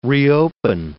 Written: reopen

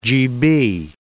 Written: GB

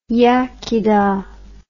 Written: iechydda

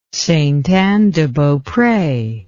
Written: Ste Anne de Beaupre

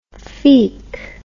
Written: feak

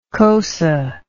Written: xosa

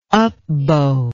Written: upbow

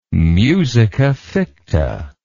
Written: musica ficta

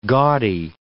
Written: gaudy

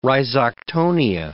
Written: rhizoctonia

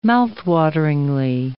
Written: mouthwateringly

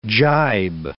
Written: gybe

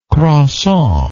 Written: croissant

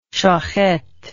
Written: shochet

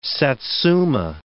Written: satsuma

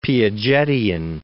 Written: Piagetian

